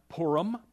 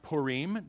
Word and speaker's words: Purim: It's pronounced Purim, P-O-O-R-I-M, or it could be pronounced Purim, depends on, you know, how you want to pronounce it. Depends on if you're from northern Israel or southern Israel, okay?